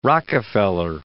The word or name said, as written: Rockefeller